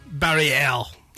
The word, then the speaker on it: Barrière: Barrière? – Barrière.